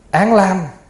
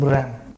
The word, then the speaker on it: ram: Án lam hay là lam cũng giống nhau, bởi vì từ chữ Phạn ram. Ram thì mình đọc có người đọc là án lam, có người đọc là lam.